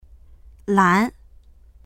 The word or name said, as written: lan2